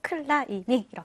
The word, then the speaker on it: climbing: 자, 그것은 바로 이 클라이밍이라는 단어에서 이 비 사운드가 묵음이기 때문에 비가 마치 없는 것처럼 클라이밍으로 발음이 되기 때문에 아무래도 우리나라 간판에서도 클라이밍 이렇게 적는 것 같아요.